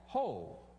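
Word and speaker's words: ho (הוֹי): it's pronounced ho. If you have a King James Bible, isn't that the first word? Ho, all ye who thirst, you know. Just says, ho.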